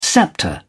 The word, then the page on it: sceptre: British English